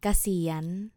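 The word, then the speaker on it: Kasiyan (เกษียณ): Kasiyan.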